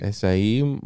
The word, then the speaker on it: Esai: Esai? How do you say his name? – Esai, yeah, I think you're right. – Esai Morales? – Morales.